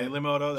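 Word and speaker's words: Peli Motto: Pelimoto. I don't know if I said that right. – Pelimoto, that's correct.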